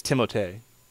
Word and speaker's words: Timothée: It's Timothée.